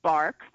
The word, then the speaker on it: barque: The alternate spelling is B-A-R-Q-U-E, which is French, and it would be pronounced bark.